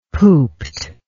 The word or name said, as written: pooped